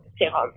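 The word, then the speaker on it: sérum: And for the other little French touch earlier in the show, serum is pronounced serum, so a little bit more but not too much, just serum.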